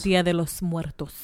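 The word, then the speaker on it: Día de los Muertos: Kind of like, and I'm going to butcher this, but you can say it and correct me after I'm done. – Okay. – The Dia de Muertos. – Dia de los Muertos.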